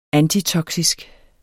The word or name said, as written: antitoksisk